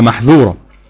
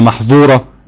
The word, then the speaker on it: محذورة: هو انا جبت مثال مش زي الناظم رحمه الله تعالى عايزه، هو الناظم هنا بيقول لك خد بالك في الذال والسين انطقهم صح، يعني هو بيقول لك على محذورة مش على محظورة بالضاد بالظاء.